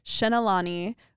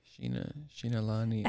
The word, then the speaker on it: Xinalani: Xinalani, which is spelled X-I-N-A-L? – A-N-I? Xinalani.